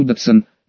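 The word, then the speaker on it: Dodgson: Сам он произносил свою фамилию Датсон, ДДСН, ряд современных словарей дает произношение ДДСН, под псевдонимом Льюис Кэрролл и изданная в 1865 году.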